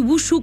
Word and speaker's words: Wushu: Vous avez la possibilité avec le Tai Chi Wushu Club Grenoble, je l'ai prononcé comme il faut ? – Oui, de exact.